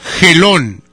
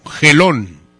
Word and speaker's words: Gelón: Gelón, con, con G de gato. Gelón. Y acento en la O. Gelón así como se escucha, señorita.